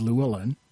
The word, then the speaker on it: Llewellyn: One of them is Meanie and the other one is Llewellyn.